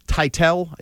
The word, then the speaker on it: Teitell: So I'm going to read you a quick little paragraph here from Beth Titel, is how I'm pronouncing it. T-E-I-T-E-L-L. – You got it.